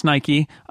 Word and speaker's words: Nike: My feeling is, and it's pronounced Nike. – yes, there you go. That's my UK showing.